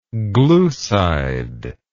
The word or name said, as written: glucide